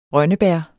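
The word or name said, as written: RØNNEBÆR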